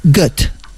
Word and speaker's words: Goethe: Y según he visto, se pronunciaría Goethe. – Mira, bueno, como pues, Gota.